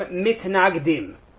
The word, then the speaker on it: Mitnagdim: So the story is going to talk a lot about Hasidim and, for lack of a better term, Mitnagdim, or Hasidim and Misnagdim, for those who prefer the Ashkenazi pronunciation.